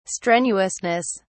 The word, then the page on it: strenuousness: British English